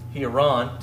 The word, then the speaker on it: Hieron: Hieron, technically, emphasis on the last syllable.